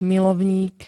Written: milovník

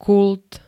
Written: kult

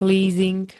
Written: lízing

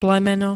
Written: plemeno

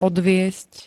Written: odviesť

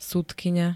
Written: sudkyňa

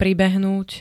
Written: pribehnúť

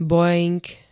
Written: boeing

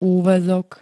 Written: úväzok

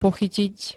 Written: pochytiť

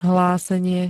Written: hlásenie

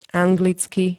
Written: anglicky

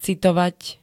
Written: citovať

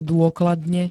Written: dôkladne

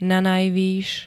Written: nanajvys

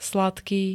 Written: sladký

